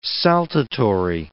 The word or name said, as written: saltatory